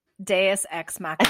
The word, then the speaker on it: deus ex machina: Am I saying that right? – Deus ex machina. – I can never say that word. Deus ex machina. You don't want to say deuce.